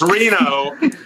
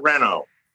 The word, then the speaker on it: Reno: And it's Reno. It's Reno, not Reno.